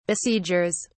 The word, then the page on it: besiegers: British English